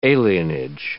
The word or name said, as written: alienage